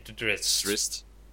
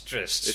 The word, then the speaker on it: Drizzt: Drizzt. Yes, Drizzt.